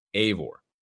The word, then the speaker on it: Eivor: First off, too, Ashraf over on Twitter says that it's spelled E-I-V-O-R and pronounced Eivor, A-Y.